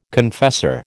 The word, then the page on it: confessor: American English